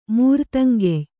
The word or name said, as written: ಮೂರ್ತಂಗೆಯ್